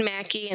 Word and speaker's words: Maki: So my name is Anne Maki, and it's spelled differently, M-A-K-I.